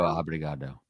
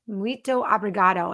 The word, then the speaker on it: Muito obrigado: Muito obrigado. – Muito obrigado.